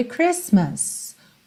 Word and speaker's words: Christmas: Voltando então, agora nós já sabemos que Christmas não tem a letra T pronunciada, ok? Então, essa primeira frase vai ser We wish you a Merry Christmas.